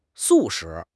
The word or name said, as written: 素食